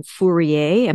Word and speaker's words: Fourier: And I was especially interested in Fourier. Am I pronouncing the name correctly? – That's my understanding of the pronunciation, yes.